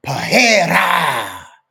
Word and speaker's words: Pereira: It was Alex Pereira. That's how you say it, Chael Sonnen. Alex Pereira.